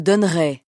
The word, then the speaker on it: donnerai: Perlu diketahui, huruf E dalam fujur tidak diucapkan. Dengarkan contoh-contoh berikut. Kata kerja done untuk subjek orang pertama singular. – Je donnerai.